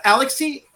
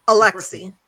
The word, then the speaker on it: Alexey: Alexei, A-L-E-X-E-Y is how it's Alexei, yeah.